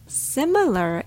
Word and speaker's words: similar: Similar is pronounced with a rhythm with the first syllable stress. It goes like ta da da, similar. So si is the strongest sound in this word.